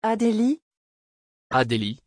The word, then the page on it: adelie: French